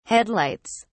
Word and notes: headlights: British English

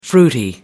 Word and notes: fruity: American English

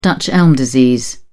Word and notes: dutch elm disease: British English

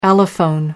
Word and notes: allophone: American English

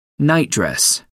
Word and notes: nightdress: American English

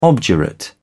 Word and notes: obdurate: British English

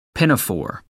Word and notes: pinafore: American English

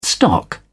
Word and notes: stock up: British English

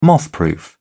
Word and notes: mothproof: British English

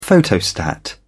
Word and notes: photostat: British English

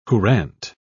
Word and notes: courante: British English

